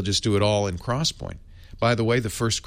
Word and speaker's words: XPoint: Intel has a new memory storage technology called CrossPoint. And the cross is an X. I couldn't make this stuff up if I tried. So it looks like XPoint, but it's pronounced CrossPoint.